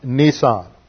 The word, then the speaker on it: Nisan: But we see here that 6 days before the Passover, Passover always occurred on the 14th day of the month of Nisan. That is not an automobile. It is spelled N-I-S-A-N, and it was the first month in the festival or feast calendar of Israel.